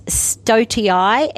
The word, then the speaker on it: stoatii: So there's Eucalyptus stoatii, S-T-O-A-T-double-I, stoatii, or it's actually pronounced stoat, the stoat's mallee.